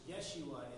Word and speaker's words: Yeshua: So the Hebrew word, how you pronounce it in Hebrew, is Yeshua.